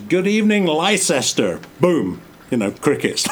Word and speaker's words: Leicester: And remember, I've just said that the town is pronounced Leicester. – Uh-huh. – And it's spelled L-E-I-C-E-S-T-E-R. Excuse me, but pronounced Leicester.